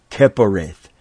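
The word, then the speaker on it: kapporeth: Kepareth.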